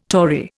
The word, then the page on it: Tory: Indian